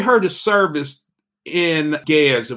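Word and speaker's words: Ge'ez: Gez, okay.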